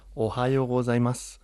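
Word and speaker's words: Ohayou gozaimasu (おはようございます): Ah, bueno, se diría Ohayou gozaimasu. Pero la forma más sencilla de decirlo es decir como el estado de Estados Unidos, Ohio.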